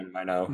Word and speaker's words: Minot: They have an Air Force base in, I pronounce it Minot. Minot? – Yeah, Minot.